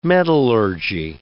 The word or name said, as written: metallurgy